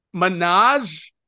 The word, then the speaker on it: Minhaj: And then and then someone goes, Minhaj? And she's like, oh, that's how you say it? And they go, yeah, Minhaj, Hasan Minhaj.